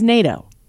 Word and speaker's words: NATO: It's NATO. – It's NATO. – Right? It rhymes with Plato. – It's the end of tornado.